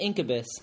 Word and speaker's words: Incubus: Listening to Incubus.